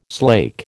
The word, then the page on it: slake: American English